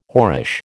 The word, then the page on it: whorish: American English